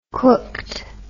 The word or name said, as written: cooked